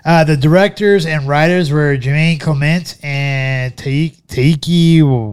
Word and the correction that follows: Taika Waititi: Taika Waititi.